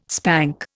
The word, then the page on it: spank: Indian